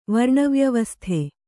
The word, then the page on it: ವರ್ಣವ್ಯವಸ್ಥೆ: kannada